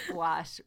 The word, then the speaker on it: gouache: Okay, so it's actually, yes, it's actually pronounced gouache.